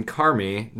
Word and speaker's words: Carmi: Fortunately for Bob and Tom, Bull Island was private land that was technically in Carmi. Now, it's spelled C-A-R. It's pronounced Carmi. – Okay. – It's spelled C-A-R-M-I.